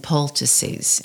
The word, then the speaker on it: poultices: I want people to know how to read the whole book, like Healing Traditions of South Louisiana, Prayers, Plants, and Poultices. Am I saying that right? Poultices. – Yes, poultices.